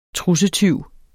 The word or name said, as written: trussetyv